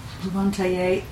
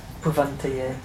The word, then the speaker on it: Bhuvantaye: Can I ask a question about Bhuvantaye? That's a short U, not a long U. – Yeah, for Bhuvam. Bhuvantaye.